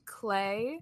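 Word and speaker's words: Clae: Those shoes have been identified as, I believe it's pronounced Clay. It's C-L-A-E.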